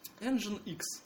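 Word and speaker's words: nginx: Собственно, вечная путаница, как же правильно произносить это название, да, Engine X, то есть типа движок X.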